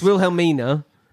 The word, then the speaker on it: Wilhelmina: Wilhelmia, Grubbly Plank. – There you go, yeah. Sorry. It's Wilhelmina. – Yes. – But you could Wilhelmia. – Wilhelmia. – That was Tom's pronunciation. Okay.